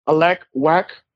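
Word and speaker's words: Alek Wek: Alak Wack. – Alak Wack. – Okay. Yeah. You can spell it as A-L-E-K.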